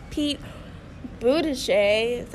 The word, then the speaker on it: Pete Buttigieg: Well, personally, I want Pete Buttigieg, that's how you say his name, to win.